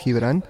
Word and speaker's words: Gibrán: So I always, when I introduce myself, it always starts off with like, my name is Gibrán or G, you know, because my name in Spanish is pronounced Gibrán, but in English, it's sometimes like very hard.